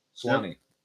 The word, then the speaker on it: Suwanee: Suwanee.